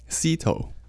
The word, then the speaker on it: Szeto: But we just had to talk in here, and Erwin said the proper way to pronounce his last name, you really didn't mind, but you were saying the way to pronounce your last name is? – Szeto. – Szeto.